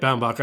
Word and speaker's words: Baumbach: Baumbach. Baumbach. Okay. – Yep, Baumbach.